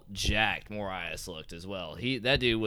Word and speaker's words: Moraes: Morales is how you say his name? – Marlon Moraes.